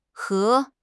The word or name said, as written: he2